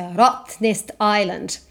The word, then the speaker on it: Rottnest Island: And when the Dutch landed on that island, they thought they were big jumping rats, and they called the island Rottnest Island, which is now, because the Dutch pronounce their vowels a little bit different, so Rottnest Island.